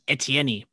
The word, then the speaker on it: Etienne: So we get Trevor Lawrence and Travis Etienne, both from Clemson.